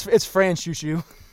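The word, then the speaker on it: Franchouchou: Franchocho. I have pronounced literally everything wrong in this whole summary. – It's Franchushu. – Franchushu.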